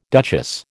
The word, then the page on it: duchess: American English